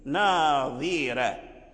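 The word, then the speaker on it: ناضرة: ترقيق الراء يعني لمناسبة كسرة الضاء ناظرة تنطق بالضاد مكسورا تحافظ على تفخيمه حتى لا يرجع دالا لأن الفرق بينه وبينه هكذا، ثم ترقق الراء لأجل كسرة الضاد ناظرة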